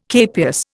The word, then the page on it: capias: Indian